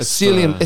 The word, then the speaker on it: psyllium: A psyllium. It's like aluminium. It's like pterodactyl. – It's like aluminium. – It's got a silent P. It's so psyllium.